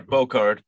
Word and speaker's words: Bocard: Bocard, as in Picard or Bocard. How is it, Thomas? – The latter, Bocard, but Bocard.